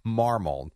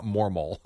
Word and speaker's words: Marmol: Marmol. – Marmol. – Not Mormol. – Marmol. – Yeah. – Oliver Marmol. M-A-R-M-O-L, I believe is how you spell it.